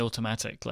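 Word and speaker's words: Automattic: Or how do you say Automatic? – I just say Automatic. Like, yeah, it's got two T's. – You can't pronounce those extra T's. Like, you just can't do that.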